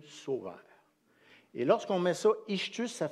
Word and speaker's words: ichthus: ichthus.